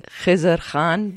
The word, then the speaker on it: Khizr Khan: I know I'm not saying it correctly. You can tell me how to say it correctly. – Khizr Khan, but you said it fine, you know.